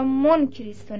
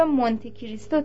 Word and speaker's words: مونت کریستو: ولی باید توجه کرد که مونت کریستو اسم خاص و ایتالیایی است و در زبان ایتالیایی آن را مونت کریستو تلفظ می نمایند و به همین جهت در تمام فرهنگ های فرانسوی مونت کریستو با ذکر حرف تا در موقع تلفظ ضبط شده است.